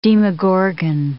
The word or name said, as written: Demogorgon